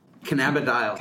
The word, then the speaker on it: cannabidiol: It's, first of all, CBD is cannabidinol. Is that correct? – I guess. – How do you say it? – This is my pronunciation is, now I'm going to say it wrong. cannabidiol. – Cannabidiol. Okay. – Yes. This is the Canadian pronunciation that you hear at the medical conferences.